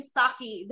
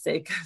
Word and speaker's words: Sake: I've been calling him Saki this whole time. – Yeah, I've also been calling him Saki. And then I read somewhere that Soph at some point wrote this little rhyme with Sake was a mistake. So yeah. I was like, okay, you know what? Let's assume it's Sake.